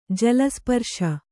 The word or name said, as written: ಜಲಸ್ಪರ್ಶ